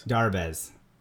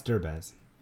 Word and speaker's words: Derbez: Darbez. – No, it's Derbez. It's Derbez. D-E-R. Derbez.